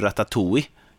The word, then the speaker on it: Ratatouille: Men sen finns det också en tecknad film som inte är lika hemsk som heter Ratatouille. Uttalar man det så? Ja, den är bra ju.